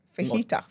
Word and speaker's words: fajita: Fajita. First of all, the J is silent.